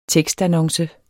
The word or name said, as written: tekstannonce